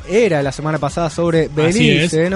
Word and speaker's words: Belice: ¿Belice o Belice? – Belice. – Belice. – Belice. Yo le voy a decir Belice.